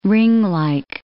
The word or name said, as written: ringlike